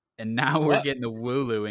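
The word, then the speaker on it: Wooloo: Wulit. Is that it, Kian? Wulit? Am I saying that right? – Wulu. – Wulu.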